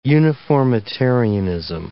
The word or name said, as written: uniformitarianism